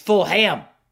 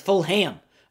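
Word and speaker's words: Fulgham: Like, yo, It's full ham. Travis, full ham.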